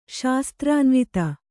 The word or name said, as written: ಶಾಸ್ತ್ರಾನ್ವಿತ